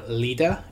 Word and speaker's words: Lod: Now, this is named after the type site of Lod, or Lida is another way it's pronounced.